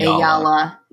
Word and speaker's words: Eyala: Ayala. Yeah, E-Y-A-L-A.